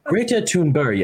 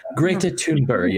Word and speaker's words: Greta Thunberg: And the whole time, this woman decided that she was going to pronounce Greta Thunberg the right way, as if she was where Greta Thunberg is from. And so the whole time she was going, Greta Thunberg, yeah? Greta Thunberg, yeah? And I was like, man, NPR, they get really like cocky.